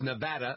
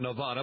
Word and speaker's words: Nevada: He did mispronounce one of those. It's Nevada, not Nevada.